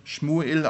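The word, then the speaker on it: Schmuel (שמואל): Samuel, der im Hebräischen Schmuel ausgesprochen wird, bedeutet zum Beispiel, Gott hat erhört.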